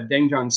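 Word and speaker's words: Doenjang (된장): That one's the doenjang. So that's the fermented soybean paste. It's a little bit different. – Tell me how to pronounce this again. – Doenjang. – Doenjang.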